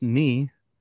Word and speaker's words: ni: The Japanese word for 2 is pronounced ni, and the Japanese word for 3 is pronounced san.